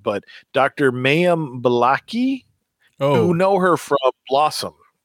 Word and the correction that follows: Bialik: Oh, yeah, that's Bialik.